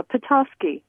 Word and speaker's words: Petoskey: Petoskey.